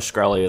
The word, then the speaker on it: Shkreli: And also, Martin Shkreli is making it $5,000 a pill or something. Well, I'm impressed he can pronounce his last name.